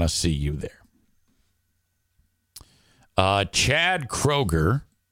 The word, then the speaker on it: Kroger: It's Kroher. It's pronounced Kroher. Ryan says undeserved abuse. Amanda says the songs give you douche chills. Kroger is how you say it.